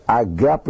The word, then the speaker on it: agape: And how is a noun pronounced? Agape. Here we go. Sounds almost like Russian or Swedish.